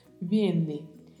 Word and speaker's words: viene: Viene. Lembre-se sempre, a letra V tem sempre um leve som de B. Então pronunciamos viene, viene e o verbo no infinitivo venir, com o vibrato do R ao final.